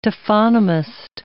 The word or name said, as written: taphonomist